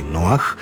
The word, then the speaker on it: Noah: U naslovu pjesme Bobija Bera Noin Arka, to jest Noah's Ark, Noah se jasno piše sa H. Engleski Noah izgovara se kao i hrvatski.